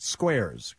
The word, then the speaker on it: Squairz: But you pronounce it Squares, correct? – That's correct.